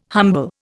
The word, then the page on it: humble: Indian